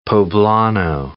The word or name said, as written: poblano